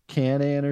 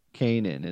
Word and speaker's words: Kanan: We said Canaan or something. It was Kanan. Kanan is how you pronounce that name because we did the gimmicks and.